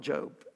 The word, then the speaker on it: Job: If you're not familiar with the Bible, it's spelled Job, but Christians, we call it Job. I don't know why. That's what we do.